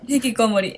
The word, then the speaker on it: hikikomori: Lo que ustedes dicen de los hikikomori es, no me acuerdo cómo se dice, hikikomori. – Hikikimori. – Hikikimori. No, a lo que iba es que aparte no solo de lo que hablabas de los hikikomori. No, hikikomori.